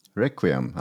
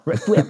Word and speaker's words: Requiem: Och ja, apropå uttal, nu tungan rätt i mun här, men eh, A Plague Tale Requiem Requiem, ja. Requiem. ja nästan, nästan. – 99 procent rätt, yes.